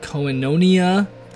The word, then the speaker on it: Koinonia: If you'd like to know the song that's playing right now, it's called Koinonia. That's how you pronounce it, in its presence.